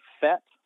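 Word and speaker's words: PhET: It's called FET, but it's spelled with a PH.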